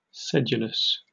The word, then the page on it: sedulous: British English